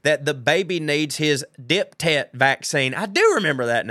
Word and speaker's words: diphtheria: And no, we don't pronounce the H after the P, so diphtheria. Okay. Uh, there are a lot of those superfluous H's in medical words like aphthous and ophthalmology.